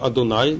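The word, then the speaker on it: Адонай: Это документ Йод, так называемый, обозначаемый буквой Йод, по имени Бога, непроизносимому имени, которое обычно произносится Адунай, Господь, и источник Э, по имени Элохим, второе Божье имя.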